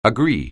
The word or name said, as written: agree